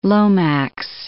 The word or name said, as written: Lomax